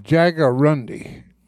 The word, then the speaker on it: Jaguarundi: The Jaguarundi.